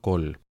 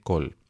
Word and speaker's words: kohl: la gente se pintaba con un pigmento llamado col, que se escribe K-O-H-L,